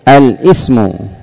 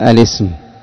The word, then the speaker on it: الاسم: وهنا أيضا يقع الخطأ الكثير عند كثير من الناطقين بهذه الألفاظ، فتجده يقول الاسم يقطع الهمزة الاسم، وهذا خطأ، إنما يقال الاسم.